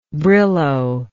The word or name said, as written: brillo